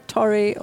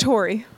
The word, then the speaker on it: Torrey: okay, so so you're uh, we were just saying before, is it Torre or Tori or how, how would you pronounce It's Tori. Tori.